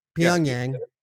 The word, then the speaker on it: Pyongyang: Pyongyang. – Pyongyang. – Pyongyang.